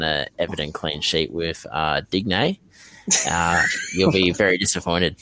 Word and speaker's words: Digne: So, also, Digne. I think that's how you pronounce it. – It's Digne, mate. It's Digne. – Digne. Well, there you go. He'll be now known as Digne.